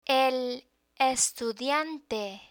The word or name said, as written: el estudiante